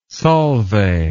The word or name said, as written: Solvay